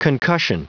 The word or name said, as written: concussion